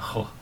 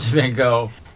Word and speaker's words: Van Gogh: But Vincent Van Gogh is what he would have said. – How do you pronounce it? – It's Van Gogh.